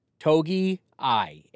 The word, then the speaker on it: Togiai: It's phonetic, okay? It's a phonetic pronunciation. Togi I, A-I.